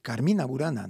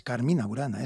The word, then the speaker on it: Carmina Burana: El título un poco está puesto es para que quede bien armonizada la cosa, pero claro, es que la gente suele decir Carmina Burana, no es Carmina Burana, es Carmina Burana. Es con la tilde, aunque yo lleva tilde, es el acento en Carmina Burana.